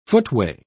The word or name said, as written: footway